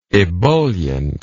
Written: ebulliently

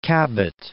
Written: Cabot Strait

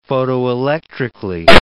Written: photoelectrically

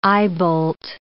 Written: eyebolt

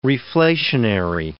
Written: reflationary

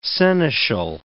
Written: seneschal

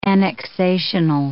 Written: annexational

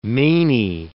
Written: meiny

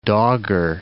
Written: Dogger Bank

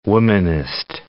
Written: womanist

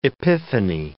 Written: Epiphany

